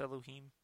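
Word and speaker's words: אדני: Second word is pronounced Elohim.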